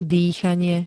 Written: dýchanie

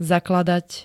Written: zakladať

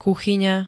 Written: kuchyňa

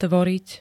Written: tvoriť

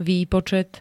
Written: výpočet

